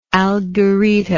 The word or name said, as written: algerita